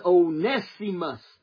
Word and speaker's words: Onesimus: And so I thank God, Onesimus, I say Onesimus a lot, but it's the correct pronunciation of it is Onesimus. Onesimus.